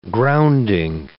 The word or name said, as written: grounding